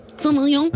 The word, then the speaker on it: Sauvignon: Sauvignon. – Am I saying that right? – Sounds right to me.